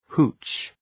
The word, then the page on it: hooch: hu:tʃ